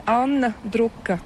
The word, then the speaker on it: Anna Druka: Oui, en letton, les voyelles sont un peu plus profondes et l'accent est toujours sur la première syllabe. C'est la grande différence avec la langue française. Ça fait une difficulté. Mais voilà, mais sinon, je m'appelle Anna Druka.